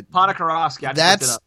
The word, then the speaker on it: Ponikarovsky: Alexei, I'm going to... – Ponikarovsky. – That's how you say the name, and you know how to say it.